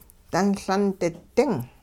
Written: dunLundi-ding

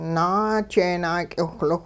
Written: naajeenak'iwhliwh

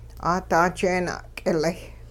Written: 'aada jeena'k'ilay

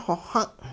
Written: xoxung'

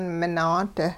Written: minaad

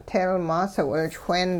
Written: teelmaas-wilchween

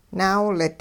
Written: naawilit

